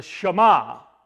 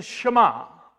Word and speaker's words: Shema: This is known as the Shema, not Shema, Shema is how it's pronounced. This is known as the Shema.